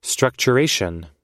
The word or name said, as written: structuration